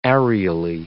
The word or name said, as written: areally